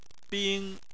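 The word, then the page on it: bing: tone 1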